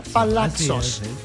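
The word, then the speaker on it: palazzos: Pues bueno, ahí están los vestidos, los palaxos, así se pronuncia, palaxos, el palaxo, bien bonito y bien hermoso para toda la señora que nos está escuchando.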